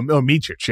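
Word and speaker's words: Micić: The how do you pronounce the new point guard? I've only seen Micic. – Oh, Micic, yes.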